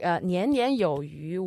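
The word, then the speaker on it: yu (鱼): So the name for fish in Chinese is yu. Yu, as a character, the pronunciation can also refer to surpluses.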